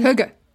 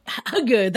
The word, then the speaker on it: hygge: It's hygge, is it? – The Danish concept of coziness, essentially. – Yes. And hygge. hugger.